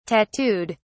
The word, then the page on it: tattooed: British English